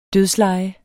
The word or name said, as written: dødsleje